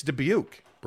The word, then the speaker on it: Dubuque: Side note to your Dubuque story, Keith Carl says, people get pissed when outsiders make fun and pronounce it as Dubuque. – Wait, people really pronounce it that way? – Dubuque? It's Dubuque, bro.